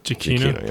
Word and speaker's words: Giacchino: Giacchino, yeah.